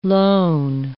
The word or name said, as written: lone